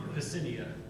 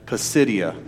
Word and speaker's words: Pisidia: pisidia pisidia okay there you go that, that, that place there in Antioch.